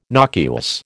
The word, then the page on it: nocuous: American English